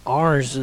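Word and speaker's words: Arenz: I think I've seen that too, but I also think Arns is how you say that. – Is it? – I'm pretty sure I've heard him say that. – Okay. It's A-R-E-N-Z.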